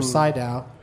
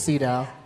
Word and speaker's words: Sydow: Or Sydow. I've never known for sure how you pronounce it. I think it's Sydow. Sydow? – Yeah.